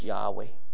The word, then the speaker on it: Yahweh: It's unpronounceable. Jewish people would not pronounce it, but we would pronounce it as Yahweh.